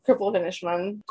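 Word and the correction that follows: Cripple of Inishmaan: Cripple of Inishman.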